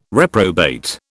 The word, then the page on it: reprobate: British English